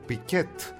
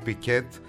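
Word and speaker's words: Pickett: Τα γαλλικά και το πιάνο με φάγανε, κατάλαβες. Και δεν μπορεί να ήταν αλλιώς, γιατί ο Wilson Piquet, η Piquet, γεννήθηκε στην Αλαμπάμα. Εκεί δεν έχουν προφορά τέτοια.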